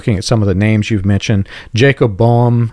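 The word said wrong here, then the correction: Boehme: I've actually since learned it's pronounced Bohme. Bohme, yeah, that sounds about right.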